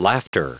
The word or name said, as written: laughter